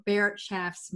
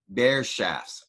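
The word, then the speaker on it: Bereitschafts: And we owe this bit of fail-safe choice making to what scientists refer to as a Barrett Schaff's moment.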